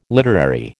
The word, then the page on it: literary: American English